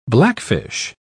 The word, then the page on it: blackfish: British English